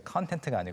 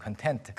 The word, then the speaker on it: content: 발음 이제 content가 아니고 content, content.